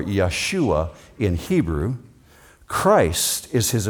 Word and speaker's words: Yeshua: In Hebrew, it's Yeshua, that's the way they would have pronounced it.